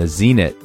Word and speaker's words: Zenit: And the Zenit, rhymes with send it, is an intermediate shoe that can point you towards harder, steeper boulders and sport climbs.